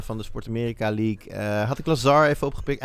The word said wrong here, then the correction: Lazard: Lazar, hoe spreek je dat? Lazar? Lazard. Alan Lazard